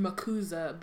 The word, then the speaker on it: MACUSA: It's a soft S. MACUSA.